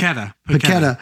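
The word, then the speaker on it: Paquetá: Pankata, I'll tell you right now. Did I say his name right? – Paqueta.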